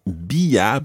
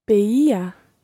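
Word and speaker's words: veía: So it's not bia, but beia. – Beia. – Yeah, it's another one of those see how many vowel sounds you can fit into the one word. Beia. – Beia. – So it goes beia, beias, beia. – Beia, beias, beia. – Yeah, with the stress each time on the E.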